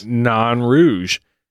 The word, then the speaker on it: Nain Rouge: Uh, as a response for the pronunciation, my French speaking friends tell me it's pronounced non. None of your business? Non rouge.